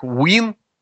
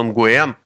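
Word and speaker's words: Nguyễn: То, что по-английски это произносится как Win, ну, как бы и хорошо, ну и что? Ну, у нас, в принципе, вроде бы русский язык, а не английский, не вьетнамский. По-русски это произносится Nguyen, ну, просто потому что это так пишется.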